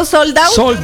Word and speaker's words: sold out: Soldado. – Sold out. – ¿No es soldado? – No. – Yo es que pensé que se pronunciaba soldado. – No, hombre, ¿cómo se va a pronunciar soldado? – Soldado.